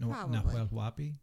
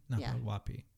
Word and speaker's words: Nahuel Huapi: The name of the lake is Lago Nahuel Huapi. – Mm-hmm. – Nahuel Huapi. So it's probably pronounced a little bit faster than that. Nahuel Huapi. Nahuel Huapi.